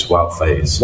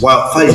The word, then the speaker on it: Wout Faes: You said it wrong, didn't you? – Pardon? – You said Wes Fant. – Yeah. – It's Wout Faes. – Wout Faes.